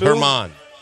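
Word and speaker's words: Germán: They're gonna start Domingo German or Herman. However you say his name. Is it a silent G? Is he a silent G? – Yes, it's Herman.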